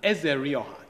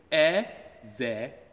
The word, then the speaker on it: Ezerioha: He said he can't pronounce my name. Last name is Ezerioha, just like it's written. Ezerioha. Ezerioha. It's easy, it's like Smith.